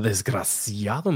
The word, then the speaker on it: desgraciado: A desgraciado? Did I say it right? A desgraciado, mira esta, coño.